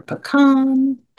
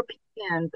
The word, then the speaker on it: pecan: Yeah, some people say pecan, some people say pecan. We accept both pronunciations, but people have opinions. Let us know what you think is the right way. I kind of think it's maybe pecan in the south and pecan in the north.